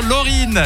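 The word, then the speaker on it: Loreen: Félicitations, Lorraine. Effectivement, c'est comme ça qu'on le prononce, la chanteuse suédoise, double vainqueur de l'Eurovision.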